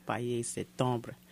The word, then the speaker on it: Pâle Septembre: It's vocalist Camille, who is French, and it's actually pronounced in French, Pale Septembre.